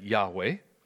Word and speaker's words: Yahweh: We tend to pronounce that Yahweh.